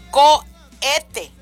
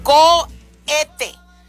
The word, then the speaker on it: cohete: Y ahorita me dijo mi compadre, pero no se pronuncia el H, nomás dice COHETE. COHETE.